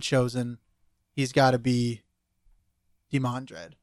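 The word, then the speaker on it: Demandred: He said it was Demondred. Yeah, that's what he thinks.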